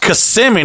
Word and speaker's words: Kissimmee: He's like, it's pronounced Kissimmee, not Kissimmee, yeah.